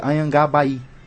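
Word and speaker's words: Anhangabaú: Anhangá Baú. – Que em tupi-guarani você pronuncia Anhangá Baí.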